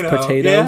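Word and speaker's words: Pot8Os: Horse name is P-O, it's spelled P-O-T-8-O. And his potato. – Name is Potatoes.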